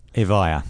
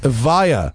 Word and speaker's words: Evija: Evija. – Evija.